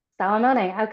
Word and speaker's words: Salomone: Salomone.